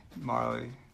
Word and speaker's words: Majerle: I think is it Majerle? Is that his last name? – Yes. – Majerle. – Marley. – Oh, it's pronounced Marley.